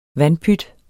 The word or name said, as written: VANDPYTS